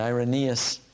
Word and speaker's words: Irenaeus: And Irenaeus is the pronunciation that you'll usually use.